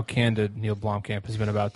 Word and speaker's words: Blomkamp: Did I pronounce that right? – Blomkamp. – Blomkamp. Perfect director for this.